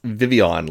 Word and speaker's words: Vivillon: Vivillon, like a papillon. – Oh, Vivillon. Okay. – It's pronounced Vivillon.